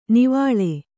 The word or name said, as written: ನಿವ್ವಳ